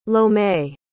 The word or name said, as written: lome